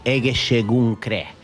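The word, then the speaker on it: Egészségünkre: You say Egeschegedre. Actually, we're going to say Egeschegunkre.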